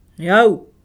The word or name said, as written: au